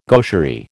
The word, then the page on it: gaucherie: American English